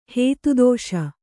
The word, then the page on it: ಹೇತುದೋಷ: Kannada